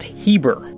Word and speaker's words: Heber: Josephus believed that the Hebrew people derived their name from Eber, as it was sometimes spelled with a leading H, and therefore pronounced Heber, leading to the word Hebrews.